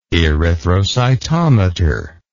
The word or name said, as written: erythrocytometer